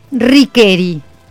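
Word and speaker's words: Ricchieri: Originalmente escrita como Riccieri, sí. – Se pronuncia como Riqueri.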